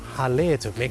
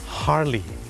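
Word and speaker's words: Harley: ฮาร์เลย์ถูกไหมครับคนไทยออกเสียงว่าฮาร์เลย์แต่เจ้าของภาษานะครับไม่ได้ออกเสียงว่าฮาร์เลย์ครับเขาออกเสียงว่าฮาร์ลีฮาร์ลีฮาร์ลีเดวิดสันประ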